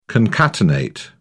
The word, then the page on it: concatenate: British English